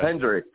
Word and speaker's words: Hendrick: Yeah, is it Kendrick or Hendrick? I want to get your name right. I forgot what you said. – Hendrick with an H.